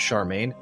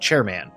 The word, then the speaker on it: Charmaine: I did almost mispronounce Charmaine. The I moved in my brain. I was like, chairman. Wait, no, that's not right.